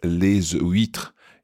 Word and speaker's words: les huîtres: Now notice it's les huîtres. You pronounce the S on les huîtres when you're talking about oysters.